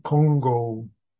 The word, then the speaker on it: Kongo: He says, "Oh, I'm a famous scholar on the Diamond Sutra, Kongo Diamond Sutra." That's the Japanese way of pronouncing the Chinese character.